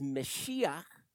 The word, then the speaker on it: Mashiach: Now, the Hebrew here is Mashiach. We pronounce it Messiah.